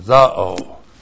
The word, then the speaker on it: zao: Z-A long O, zao.